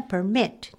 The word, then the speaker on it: permit: で、すいません。ちょっと私、さっきワードテストやりながらね、あれれと思ったんですけども、さっきのね、ワードテストの発音ね、 permit って呼んでたんですよ。 – はい。 – はい。です。でね、 permit はアクセントとしてあるんだけども、その場合は許可状とか名詞で使うことがほとんどなので、うん、この言葉はやっぱりどうした時には permit、ね、後ろにアクセントが来る、はい、ということをちょっと思っておいて、はい、いただく方がいいかなと思います。